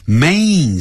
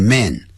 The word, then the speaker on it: main: main 是主要的 main, 不要念成 man, man idea, 要 main idea, a pain, painful,不要念成 painful, 所以每一个发音呢，这个 a 加 n 的音的时候，一定要念成 a pain pain in my chest when I breathe.